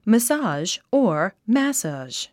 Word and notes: massage: məsɑ’ːʒ; マッサージ

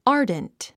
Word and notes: ardent: άːrdnt; アーデント

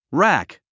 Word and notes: rack: rǽk; ラク

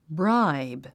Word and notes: bribe: bráib; ブライブ

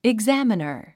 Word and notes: examiner: iɡzǽmənər; イグザァマナァ

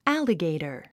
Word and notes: alligator: ǽliɡèitə r; アァリゲイタァ

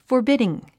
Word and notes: forbidding: fə r bídiŋ; フォビディン

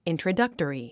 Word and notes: introductory: ìntrədʌ́ktəri; イントラダクトリィ

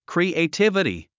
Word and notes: creativity: krìːeitívəti; クリエイティビティ

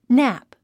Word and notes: nap: nǽp; ナァプ

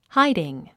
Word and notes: hiding: hǽidiŋ; ハイディン

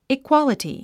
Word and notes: equality: ikwάləti; イクワラティ

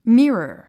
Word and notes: mirror: mírə r; ミラー

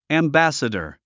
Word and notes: ambassador: æmbǽsədə r; アンバァッサダァ

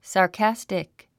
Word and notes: sarcastic: sɑː r kǽstik; サーカスティック

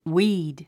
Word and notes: weed: wíːd; ウィード

